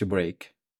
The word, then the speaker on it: brake: Emergency y break es B-R-E-I-K. – Emergency break.